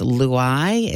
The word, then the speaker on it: Luai: They named him, I'm guessing you pronounce this Luai. It's L-U-A-I. – L-U-A-I, yeah. – Luai.